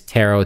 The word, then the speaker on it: Tero: My name is Tero, T-E-R-O.